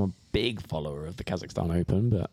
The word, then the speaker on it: Kazakhstan: Kazakhstan. – Yeah, Open on the Challenge Tour. – Kazakhstan.